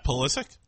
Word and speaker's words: Pulisic: Is it Pulisic? – Pulisic? – No, I think you said it right.